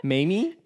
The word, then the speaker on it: MAME: It's MAME, so I don't know if it's MAME or mommy. – I'd like MAME. – Okay. – MAME? – Yeah, MAME.